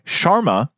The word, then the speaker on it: Sharma: So it's one word, Shiv Kumar, followed by Sharma, which is exactly as it sounds. By the way, Santoor, the instrument, is spelled S-A-N-T-O-O-R.